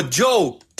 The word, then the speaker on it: Djo: Bueno, pues lo pueden encontrar en las redes sociales así como Joe. Claro que se escribe D-J-O.